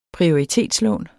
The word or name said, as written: prioritetslån